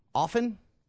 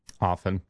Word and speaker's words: often: like often. – Often. – There's a T in it. I definitely say often. – A lot of people do, but the T is supposed to be silent.